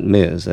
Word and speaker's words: Ms.: And there are some indications that the initial pronunciation was Miss and not Ms. as it's pronounced now.